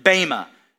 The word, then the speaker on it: bema: This Greek word for judgment seat is actually bema, B-E-M-A. Some pronounce it bema. It's like tomato, tomato, whatever. I like to say bema. It is the B-E-M-A, the bema judgment seat of Christ.